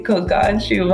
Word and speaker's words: Colgate: Oh, Colgate, Colgate, Kogachi. Wow. – Kogachi. – Yes, we say ch. Yes. Kogachi. – Yeah, like t, We would Brazilian pronunciation We would say chi, chi.